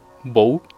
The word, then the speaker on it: Bo: Como su nombre se escribe B-O, Bow. Y eh, cuando lo abuchean, porque hay muchos videos donde lo abuchean, Donde dicen Bu eh, en inglés, eh, entenderás que una B con dos O's es, se pronuncia como un bu.